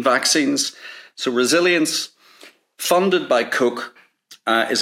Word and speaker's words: Koch: the Koch brothers, the Koch Industries is a huge private sector. And by the way, Koch is spelled K-O-C-H.